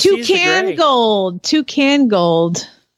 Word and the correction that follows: Tuscan Gold: Yeah, but his name is Tuscan Gold, but you pronounced it incorrectly as Toucan Gold.